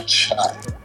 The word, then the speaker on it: Chaap: I spell it with two A's because I'm an Atlanta and I'm African. – So okay. – It's Chaap with two A's.